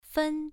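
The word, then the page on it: fen: tone 1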